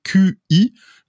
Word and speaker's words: Qi: Euh, alors ça s'écrit QI, mais ça se prononce Qi, c'est ça C'est ça.